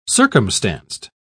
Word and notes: circumstanced: British English

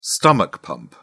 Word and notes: stomach pump: British English

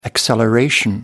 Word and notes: acceleration: American English

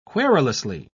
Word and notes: querulously: British English

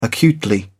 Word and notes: acutely: British English

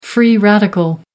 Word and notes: free radical: American English

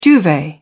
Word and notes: duvet: British English

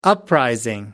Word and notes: uprising: American English

